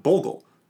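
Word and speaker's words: Boggle: We got Sorry and we've got Bogle.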